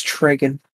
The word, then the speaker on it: Tregan: It's Tregan. – Oh, sorry, Tregan. I'm a fake fan, I guess now. – He's a fake fan. It's fine. You can pronounce it either way. – All right. – It doesn't matter. It's like tomato.